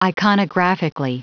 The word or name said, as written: iconographically